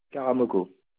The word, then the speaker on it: Karamoko: Karamoko.